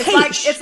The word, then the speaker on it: Heche: I mean, but it is Heche.